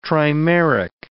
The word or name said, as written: trimeric